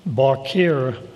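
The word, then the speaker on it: bakir (בחיר): And in each verse, elect is translated from the same Hebrew word, which is pronounced bakir.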